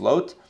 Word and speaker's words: Lot: I'll go Lot, because that's the Hebrew pronunciation is Lot.